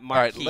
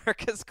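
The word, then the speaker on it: Marques: Well, downstairs we were talking about this story, and I informed him, you have been saying Marcus Colston's name incorrectly for the last 3 years. It's not Marquise.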